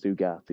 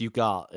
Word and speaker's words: Ugarte: Ugarte? Is that how you pronounce it? – Well, it's Ugarte if it's not got an accent on the E.